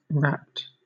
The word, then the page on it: rapt: British English